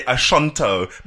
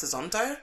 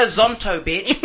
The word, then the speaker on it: Azonto: It's Azonto, bitch. Azonto, bitch. – Not a shunt.